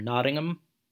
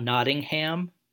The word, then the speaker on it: Nottingham: as my friend and former colleague Martin would tell me that he was from Nottingham and not Nottingham.